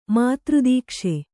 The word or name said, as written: ಮಾತೃದೀಕ್ಷೆ